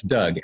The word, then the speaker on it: Doug: That's Doug. And I had to spell it D-O-U-G.